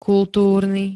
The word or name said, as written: kultúrny